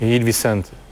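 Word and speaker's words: Gil Vicente: G ile başlıyor. Böyle Hil derler telaffuz olarak. G ile başlıyor ama Hil. – Hil Vicente.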